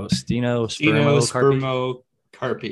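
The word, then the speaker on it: stenospermocarpy: It's S-T-E-N-O S-P-E-R-M-O-C-A-R-P-Y. So Stenospermocarpi, yeah.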